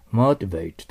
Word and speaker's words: motivate: その次、日本語で読むと、モチベートとなりますけども、 motivate と発音しますね。